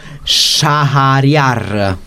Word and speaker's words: Shahriar: Stiamo nell'harem, precisamente, del sovrano Shahriar.